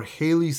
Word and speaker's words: Hailee: Hailee, right? That's how you say it.